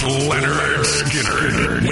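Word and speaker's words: Lynyrd Skynyrd: It's pronounced Leonard Skinner with Hairball tonight.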